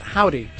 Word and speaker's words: Joudy: It is spelled J-O-U-D-Y, but it is pronounced Howdy.